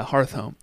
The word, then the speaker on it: Hearthome: H-E-R-T-H. – Hearth. – And then pronounce this word, O-M-E. – Hearthome. West of Hearthome City. That sounds right. Yeah, Hearthome. – Yeah, Hearthome.